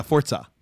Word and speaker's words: Forza: Am I saying that right? – Yeah, Forza.